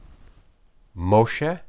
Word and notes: moshe: Hebrew

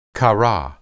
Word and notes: qara: Hebrew

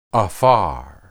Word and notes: aphar: Hebrew

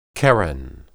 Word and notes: qeren: hebrew

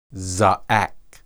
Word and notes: zaaq: Hebrew

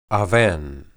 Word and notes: awen: hebrew

